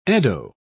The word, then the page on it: eddo: British English